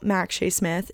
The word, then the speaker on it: Mak Shea Smith: So there is also a video podcast on my own YouTube channel, Max Shea Smith, if you want to watch the video unedited, I said it right the first time, but it just sounded very wrong, version of this podcast.